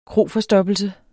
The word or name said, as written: kroforstoppelse